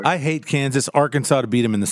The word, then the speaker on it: Arkansas: Arkansas has a silent S.